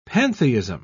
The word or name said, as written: pantheism